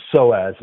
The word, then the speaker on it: psoas: The psoas muscle.